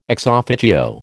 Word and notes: ex officio: American English

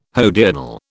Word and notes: hodiernal: British English

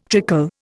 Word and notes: trickle: Indian

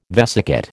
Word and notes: vesicate: American English

